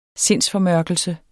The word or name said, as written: sindsformørkelse